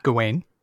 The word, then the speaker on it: Gawain: Gawain and Guinevere almost look the same to me.